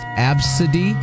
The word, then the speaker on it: ABCDE: Like, one person had ABCDE pronounced Absidy, or ESPN pronounced Espen, Ursula, Bertha, and then on the list is Todd.